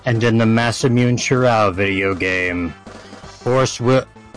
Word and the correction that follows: Masamune: I'm sorry, I need you to repronounce that immediately. – I think it's Masamune. – Masamune.